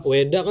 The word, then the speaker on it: Weda: Weda kan Veda tulisannya pakai V. v. – Tapi dibaca W. Iya, kita kita diadaptasi jadi bahasa Indonesia jadi W gitu. Oh. Oh gitu. Iya, jadi Weda kita bacanya gitu.